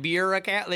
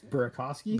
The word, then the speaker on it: Burakovsky: If you turn on SportsCenter, they're like Burakat. They put like weird emphases on weird. How do you say that guy's name? – It's Burakovsky.